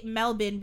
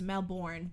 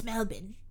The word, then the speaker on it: Melbourne: But the way, like, we in Canada and the States would pronounce that word is Melbourne. B-O-U-R-N-E. Mm. But they're like, no, it's Melbourne.